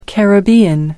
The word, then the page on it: caribbean: American English